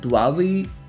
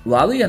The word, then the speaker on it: Huawei: So Chinese giant Huawei, yes, that's how it's pronounced. Huawei announced three new tablets and a smartwatch this past week.